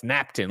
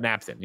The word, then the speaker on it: Knapptain: But Jim Knapp is spelled, Knapp is spelled K-N-A-P-P-T-A-I-N. So that's Napton, like that. Like Captain, but Napton. You know what I mean? K-N-A-P-P-T-A-I-N.